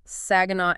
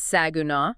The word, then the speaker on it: Saginaw: But then we have Arlington, Grand Prairie, Mansfield, Burleson, Benbrook, Azle, Saginaw, and by the way, if you want to flag yourself as an outsider, pronounce that last one wrong, it's Saginaw. – Saginaw, yes. – We also have Hazlitt, Keller, Southlake, Grapevine, Colleyville, that's C-A-W-L, evil, folks.